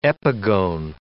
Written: epigone

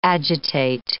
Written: agitate

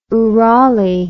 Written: oorali

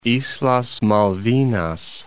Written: Islas Malvinas

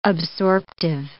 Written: absorptive

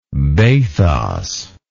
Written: bathotic

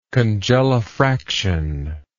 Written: congelifraction

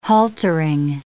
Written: haltering

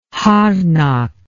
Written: harnack

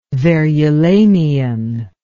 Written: verulamian

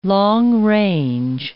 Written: long-range

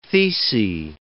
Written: thecae

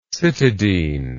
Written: cytidine

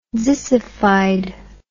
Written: zissified

